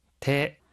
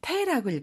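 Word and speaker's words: 테 (手): 테 손수라고 하는 한자는 음으로는 슈, 뜻으로는 테라고 읽어요.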